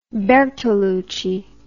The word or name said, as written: Bertolucci